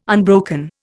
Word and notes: unbroken: Indian

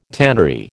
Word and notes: tannery: American English

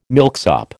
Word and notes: milksop: American English

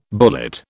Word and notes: bullet: British English

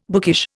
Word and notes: bookish: Indian

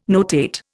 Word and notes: notate: Indian

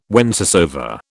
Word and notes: whencesoever: British English

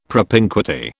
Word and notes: propinquity: British English

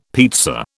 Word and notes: pizza: British English

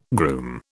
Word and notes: groom: British English

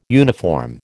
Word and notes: uniform: American English

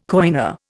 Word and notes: coiner: Indian

